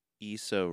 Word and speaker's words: Issa Rae: Speaking of bad pronunciations, I said I pronounced Issa Rae's name wrong. It should be Issa. I said Issa.